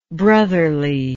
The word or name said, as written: brotherliness